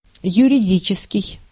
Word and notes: юридический: /jʉrʲɪˈdʲit͡ɕɪskʲɪj/

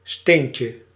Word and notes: pierre: France (Paris); [yn pjɛʁ]

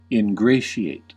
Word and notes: ingratiate: American English; /ɪnˈɡreɪ.ʃi.eɪt/

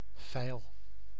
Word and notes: vijl: [vɛɪl]

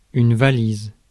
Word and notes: trunk: American English; /tɹʌŋk/

